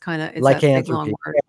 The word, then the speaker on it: lycanthropy: It's lycanthropy.